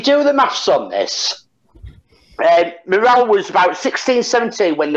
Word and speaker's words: Miral: I think it's pronounced Morale, M-I-R-A-L, and that's Tom and Bolana's daughter.